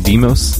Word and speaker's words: Deimos: It's pronounced Phobos and Deimos. Thank you.